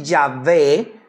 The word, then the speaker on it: Yahvé: También se le puede decir Yahvé, con acento en la E, y los diferentes compuestos de este nombre.